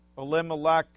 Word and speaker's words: Elimelech: Famine in the land, journeying to Moab, marriage, the death of Elimelech and Mahlon and Chilion. And Bonnie, you got those dead on right, the pronunciation.